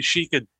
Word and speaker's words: Yashica: Obviously, you've yourself, as you've been taking some shots with, I think, is it a Yashica? Is that the way you pronounce it? – Yeah, it's the Yashica D, which is a sort of, I think, early 60s, what they call a twin lens reflex.